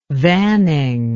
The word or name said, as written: vanning